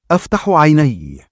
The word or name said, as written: 2afta7u 3aynayy